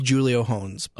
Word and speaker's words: Julio Jones: It's pronounced Julio Hones.